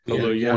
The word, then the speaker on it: Kaluuya: Kaluuya. – Or the lead again. Kaluuya. – Kaluuya.